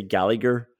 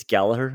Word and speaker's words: Gallagher: And I hear a lot of commentators and English people say Gallagher. I have to tell you, it's Gallagher. I'm happy to correct people.